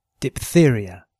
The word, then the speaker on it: diphtheria: PH before TH is often pronounced P. Diphtheria.